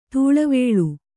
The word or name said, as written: ದಳವುಳ